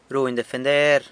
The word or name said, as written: runy defender